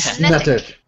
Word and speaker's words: Snettik: But username, wow, I'll spell this out because I don't think I can say it phonetically. – Snetic. – Yeah. – Snetic. – S-N-E-T-T-I-K.